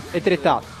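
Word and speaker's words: Étretat: Etretat, Francia.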